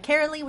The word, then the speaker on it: Cearley: Oh, you can find me on Twitter and Instagram as well with my name, Julia Carely, which is spelled JULIACEARLEY.